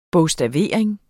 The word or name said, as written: bogstavering